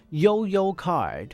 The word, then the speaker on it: 悠游: 好，他讲说呢，这个不管是一卡通啦，或是悠游卡啦，这一类的 Easy Card，好， The Smart Card 就是有智慧的卡， Used in the Taipei MRT System，就是台北捷运的时候会用到的这个卡， Is pronounced，就是发音叫做什么呢， As 悠游 card，所以就是悠游卡。好， You can buy one from any convenience store.